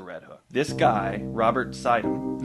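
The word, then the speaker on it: Suydam: I looked up on, because I wasn't sure how to pronounce the name because it's spelled Sudam, but according to pronunciation of upstate New York places and names, it's pronounced Sidham.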